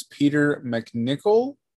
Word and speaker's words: Peter MacNicol: We should we should mention that uh, the, the, gentleman we're referring to, his name is Peter MacNicol. Mm-hmm. I believe is how you say it. Yes.